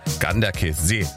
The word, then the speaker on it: Ganderkesee: Herzlichen Dank nochmal für die Aussprachetipps, die schnell reinkamen von unserem Radioarzt Jürgen in Goch, der mir gleich geschrieben hat, Ganderkesee wird das ausgesprochen.